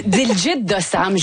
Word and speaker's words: Diljit Dosanjh: Et on va commencer cette fois-ci avec le chanteur indien Diljit Dosanjh. – Oui, ben c'est comme ça que je le prononce aussi. Diljit Dosanjh, je sais pas si vous l'avez croisé ou entendu.